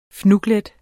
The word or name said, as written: fnuglet